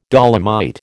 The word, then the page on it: dolomite: American English